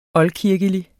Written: oldkirkelig

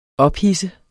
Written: ophidset

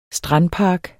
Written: strandpark

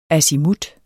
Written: azimut

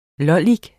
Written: lollik

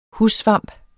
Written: hussvamp

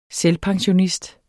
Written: selvpensionist

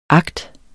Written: agt

